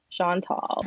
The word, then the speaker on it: Shantal: Yeah, like Sean is tall. Shantal.